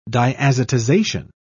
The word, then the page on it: diazotization: British English